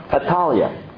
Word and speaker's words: Athaliah: Her name is Athaliah. – That's how you say it.